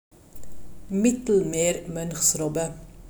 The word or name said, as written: Mittelmeermonchsrobbe